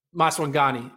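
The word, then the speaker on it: Maswanganyi: Terrence Laird in the prelims of the 4x1 looks multiple times at Sean Masagawa. Am I saying his name right? I just want to make sure I'm saying his name right. – Maswangani. – Excuse me. Apologies, Sean.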